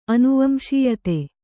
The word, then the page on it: ಅನುವಂಶೀಯತೆ: anuvamsīyate